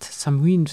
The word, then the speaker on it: Samhain: Ich habe ja schon mal eine Folge über Samhain gemacht oder Samhain gemacht. Samhain, so wird es eigentlich ausgesprochen.